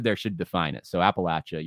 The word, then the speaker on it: Appalachia: It's pronounced Appalachia.